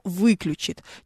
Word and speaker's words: выключит: Ну, к примеру, очень сильная приставка «вы», как правило, оттягивает ударение на себя. Да, то есть включит, но выключит.